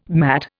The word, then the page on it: matt: Indian